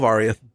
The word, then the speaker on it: Oriath: Oriath? Yeah, yeah, uh, Fall of Oriath. – How, Oriath. How we pronounce okay, it.